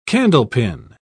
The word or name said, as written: candlepin